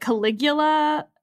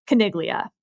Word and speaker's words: Caniglia: I unfortunately was calling it Caligula behind its back, but now that we're going to talk about the case to its face, I think I have to say it correctly, which appears to be Caniglia versus Strom.